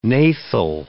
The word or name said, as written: gnathal